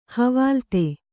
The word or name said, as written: ಹವಾಲ್ತೆ